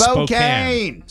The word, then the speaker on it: Spokane: It's Spokane.